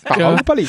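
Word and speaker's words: Baahubali: Ich glaube, die Betonung ist auf dem A, aber ich kann es nicht aussprechen. Bahubali. Wird's noch oberbayerischer. – Bahubali.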